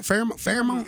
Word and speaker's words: pheromone: Pheromone? – Close enough. – F-A-I-R-M-O-U-N. Pheromone. – P-H-E-R. – Oh, pheromone. Pheromone. – Yes, that's it. – I'm talking about pheromone. – It's close enough. – F-A-I-R. Mourn. – P-H-E-R-O.